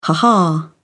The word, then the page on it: ha-ha: British English